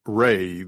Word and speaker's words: Re: The name was Jack's choice, short for Reynard, of course, but also the correct pronunciation of Re, the Egyptian sun god.